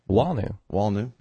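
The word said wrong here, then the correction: Wallnau: Walnow. I said it wrong.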